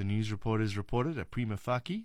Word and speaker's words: prima facie: The main stress falls on the first syllables of the second word, fei, with a secondary stress on the first word, pri. Prima facie is the way it should be pronounced.